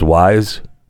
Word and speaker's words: Wieze: At the old, I think it's pronounced Wize. W-I-E-Z-E, don't.